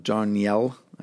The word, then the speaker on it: Darnielle: John Darnielle, or John Darnielle, I think is how it's pronounced, is still leading that group.